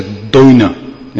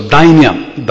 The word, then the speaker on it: dainya: If I should pronounce it properly, I should say doina. Yeah? How do you say it properly in Bengali? Doina. Yeah? So dainya.